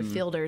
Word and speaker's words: Fiedler: Well, so my whole life, it's spelled Fiedler, but everybody's always said Jennifer Fielder. So that's where Smithfield comes from, is Trey Smith. Jennifer Fielder is what I go by.